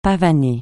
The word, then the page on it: pavaner: francais